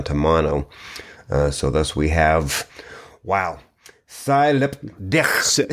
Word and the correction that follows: sie liebt dich: I've heard it pronounced a couple of different ways. sie liebt dich. So yes, she loves you, but uh, in German.